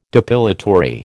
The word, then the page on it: depilatory: American English